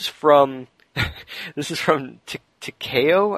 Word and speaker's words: Takeo: Small correction, the nickname is read like Takeo. He still puts T-A-K-E-O.